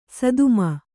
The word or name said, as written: ಸದುಮ